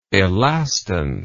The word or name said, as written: elastance